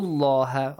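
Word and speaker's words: الله: Fadhkuru Allah. Allah is pronounced with a heavy sound here.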